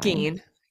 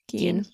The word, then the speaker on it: Gein: Um, and then they, Lionel brings up like a case from 1957, Ed I don't know how to say it, Ed Gein or Ed Gein. Gein. Gein.